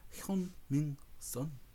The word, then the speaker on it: Heung-min Son: Heung-min Son.